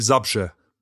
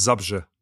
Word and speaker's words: Zabrze: Also wie heißt dieser Ort? Weil ich kann ihn nicht aussprechen sauber. – Der Verein oder Ort? – Beides. Der Verein? – Der Verein heißt Górnik Zabrze. – Górnik Zabrze. – Und die Stadt heißt Zabrze. – Zabrze.